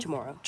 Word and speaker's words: Chamorro: How do you say it? Chamorro? – Chamorro.